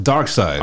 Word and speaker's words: Darkseid: Darkseid.